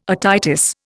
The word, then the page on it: otitis: Indian